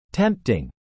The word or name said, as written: tempting